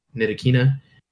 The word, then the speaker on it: Ntilikina: If Kemba Walker gets locked up by Frank Ntilikina, how do you pronounce it? Is it Ntilikina? – I think you said it right the first time. – Ntilikina.